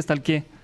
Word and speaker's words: stalk: S-T-A-L-K. Ajá. Pero se dice stalkeando o estoqueando. – No, stalk. – Yo digo stalkeando. No sé cómo se diga correcto, pero es como que, te stalkeé.